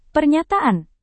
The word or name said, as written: pernyataan